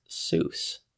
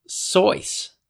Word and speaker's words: Seuss: Well, it's spelled S-E-U-S-S, but guess what? Not pronounced Seuss. Pronounced Sois. Theodore Sois Geisel. And so Dr. Sois. You could be really pedantic and say. – I love it. – However, I think it rhymes with Mother Goose.